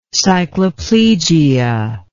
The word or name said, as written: cycloplegic